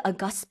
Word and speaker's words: august: The stress is on the second syllable in this case, meaning something that has great importance, especially of the highest social class. We call it august, and they're really describing themselves here, right?